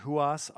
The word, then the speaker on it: Ruas: First one, Ruas, R-U-A-S. They did release this, which is a self-titled of the band name, Ruas, R-U-A-S.